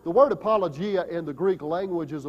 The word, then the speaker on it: apologia: The word defense is the translation of a good Greek word that is pronounced apologia, from which we get our English word apology, and that's not the idea of Peter's word here.